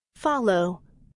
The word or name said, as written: follow